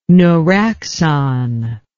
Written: neuraxon

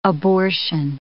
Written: abortion

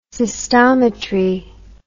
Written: cystometric

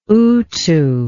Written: utu

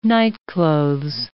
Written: nightclothes

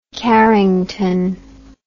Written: carrington

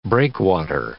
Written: breakwater

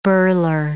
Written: birler

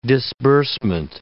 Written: disbursement

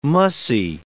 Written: mussy

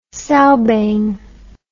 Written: sowbane

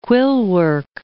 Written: quillwork

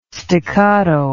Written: staccato

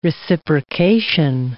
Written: reciprocation

